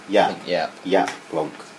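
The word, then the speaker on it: Jaap Blonk: There's a Dutch artist, composer and provocator, Jap Blonk, I think that's pronounced. It's a Dutch... How do you pronounce that? J-double-A-P. Yap Blonk.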